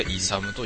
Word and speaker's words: ISM: ISAM の方ね、 ISM って書いて、でイサムと読む、当たり前だけど、でそういう名前でやってますね。ビーストでハンターでやっております、レベルが 18 かな、レベルが 18、やっぱりあのゲームぐるぐるぐるぐる同じとこ回るから、それがアウトの方はあれかもしれないね。